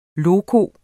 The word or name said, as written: loco